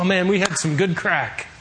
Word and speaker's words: craic: We had some good crack. It's spelled C-R-A-I-C.